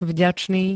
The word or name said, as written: vďačný